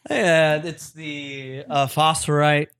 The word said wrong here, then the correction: Focusrite: Well, I know I'm probably saying that wrong. Focusrite, whatever.